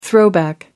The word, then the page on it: throwback: American English